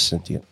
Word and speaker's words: sentient: I always thought it was sentient. – Just think S-E-N-S-H-E-N-T. Sentient.